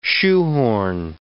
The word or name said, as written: shoehorn